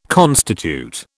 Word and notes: constitute: British English

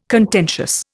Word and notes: contentious: Indian

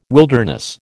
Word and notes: wilderness: American English

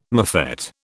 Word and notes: mofette: British English

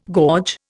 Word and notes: gorge: Indian

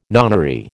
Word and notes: nonary: American English

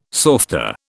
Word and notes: softa: British English

